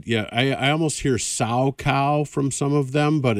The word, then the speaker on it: Salchow: Is Salchow? Yeah. Is that how you pronounce it? I think you don't sound the H.